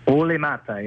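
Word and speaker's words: Olli Määttä: Olli Maatta, yeah.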